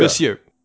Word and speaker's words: Monsieur: Monsieur doesn't have the R at the end. So Monsieur. – Monsieur. Monsieur Mala. – Monsieur Mala. All right. So keeping the majority of Monsieur Mala's pre-crisis origin intact,